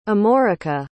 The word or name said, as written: amorica